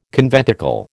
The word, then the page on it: conventicle: American English